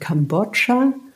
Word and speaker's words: Kambodscha: Aber nee, Kambodscha hast du richtig ausgesprochen.